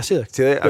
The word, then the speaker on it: celiac: Now, is there a reverse effect of, like, if you avoid, like, I've been told this anecdotally, my sister is celiac. I don't even know how to say it. – Yeah, celiac.